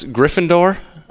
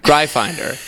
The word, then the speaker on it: Gryffindor: And I made, you had never watched any, how did you pronounce Gryffindor? – Gryfinder.